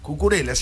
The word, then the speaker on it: Cucurella: Acá le tiene miedo a Cucurella. – Cucurella, sí, lo dije bien.